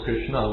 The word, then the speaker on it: Krishna: Of course, Krishna, with an A at the end, it's a short A. That's not Draupadi. It's not Draupadi. That she is Krishna with a long A. And of course, in Hindi, you may have just Krishna. But to have the A at the end doesn't turn it feminine unless you put long A. But don't put a long A. But the short A is okay.